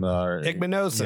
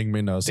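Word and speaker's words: Igbinosun: Same with I can't pronounce his last name. – Igbinosen. – Yeah, Igbinosen.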